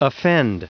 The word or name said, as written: offend